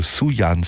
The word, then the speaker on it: Sufjan: Die Rede ist von Sufjan, nennen ihn viele. Meines Wissens wird das F bei der Aussprache geknickt, also Sufjan Stevens, und seinem neuen Album The Age of Odds, das mit einem 23-minütigen Schlusstitel endet.